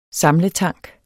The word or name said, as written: SAMLETANK